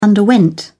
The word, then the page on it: underwent: British English